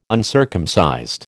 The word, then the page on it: uncircumcised: American English